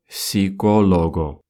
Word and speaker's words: psicólogo: В испанском языке при сочетании «ps» первую букву «p» мы не произносим. То есть психолог в Испании будет «psicólogo». Не «psicólogo», а именно «психолого».